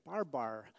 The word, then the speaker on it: barbar: Well, to the Greek during Paul's day, when someone spoke in anything but Greek, they were considered a barbarian, barbar. That's the actual two syllables here.